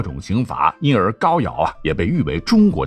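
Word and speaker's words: 皋陶: 陆安地区的建制其实啊和曹县一样历史悠久，在夏朝时属于高尧后裔封地。这个高尧的尧是个陶瓷那个陶哈，一定要读成尧。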